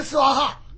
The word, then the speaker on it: 娑婆诃: 举一个例子吧，娑婆诃现在都念娑婆诃，娑婆诃实际是斯瓦哈，那就念斯瓦哈，那不念娑婆诃，在古代就念斯瓦啊，这都是平常我们念的佛陀耶，那就是不大呀，那就是不大呀，不念佛陀耶。